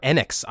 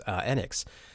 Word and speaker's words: Enix: Yeah, EA more than Square Enix, or Enix, I realized is how it's pronounced. I did not know this, Enix.